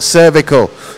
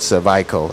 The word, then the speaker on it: cervical: That's cervical, not cervical. Just say it right, yes. The thing is your cervix, not your cervix. So cervical cancer, yes.